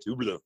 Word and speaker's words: Hublot: We put a, I'm going to mispronounce this. We put a Hubolt. Hubolt. – This is a watch podcast, Paul, okay? You need to pretend you're qualified to be on here. Although most people would appreciate that you mispronounced it. – I think Alex saw the photos of before and after of that Hublot with under the plasma cutter. – Hublot.